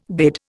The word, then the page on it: bid: Indian